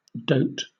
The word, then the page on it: dote: British English